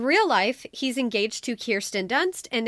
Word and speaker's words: Kirsten: It is pronounced Kirsten. For those who want to know, I have looked it up. I've heard him say it. It is Kirsten, but spelled Kirsten.